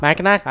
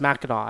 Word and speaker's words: Mackinac: Mackinac? I don't know. It's spelled Mackinac, but it's pronounced Mackinac, I feel like.